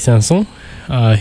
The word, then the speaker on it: Saint-Saëns: This is by Camille Saint-Saëns, his Morceau de Concert for French Horn and Orchestra. And that pronunciation is thanks to me going abroad to Paris.